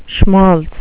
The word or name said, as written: schmaltz